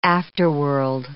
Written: afterworld

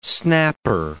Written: snapper

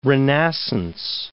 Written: renascence

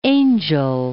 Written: Angel Falls

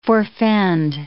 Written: forfend

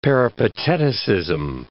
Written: Peripateticism

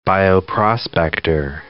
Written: bioprospector

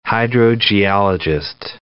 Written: hydrogeologist

